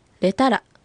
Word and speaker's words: レタラ: 次にレタラ、ここは最後のラは小さい発音ですので注意して発音してください。